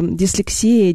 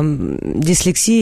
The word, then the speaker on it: дислексия: А дислексия или дислексия, так как это все-таки термин а, зарубежный, то его можно произносить на западной манере дислексия, дислексия. Вот, как-то по-русски у многим э, лаконичнее, что ли, звучит э, как и дисграфия, так и дислексия произносить.